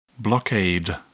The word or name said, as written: blockade